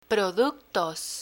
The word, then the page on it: productos: Spanish